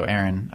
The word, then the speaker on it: Aaron: So Aaron, of course, AAARON, and then Plaszek, PLASEK.